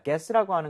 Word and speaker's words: guess: 자, guess라고 하는 것은 추측하다, 맞춰보다라는 뜻입니다. 여기서는 어떻게 보면은 you가 묵음이라고 생각을 해야 돼요. 그래서 구에 이렇게 가지가 않죠. 무조건 guess. – Yes. – guess가 맞는 발음이고요.